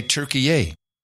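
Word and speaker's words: Türkiye: We should say Turkey-ay. It's the new way to pronounce Turkey. It's Turkey-ay.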